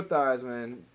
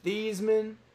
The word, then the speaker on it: Theismann: So NFL MVP Joe Theismann was convinced to change the pronunciation of his name from Theismann to Theismann because it sounds like Heisman.